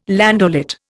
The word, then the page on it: landaulet: Indian